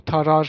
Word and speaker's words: Tarrare: tarrare diye yazılıyor. Nasıl okunur bu? Tarar falan diye Aynen tarar diye.